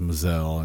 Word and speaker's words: Measel: Go to my Facebook page, you know, Chris Lee or Christopher Lee Mazel. My last name, my real last name is Mazel. It's spelled M-E-A-S-E-L.